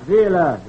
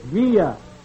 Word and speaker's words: Villa: It's not Villa. It's Villa. The L, she is pronounced like the Y.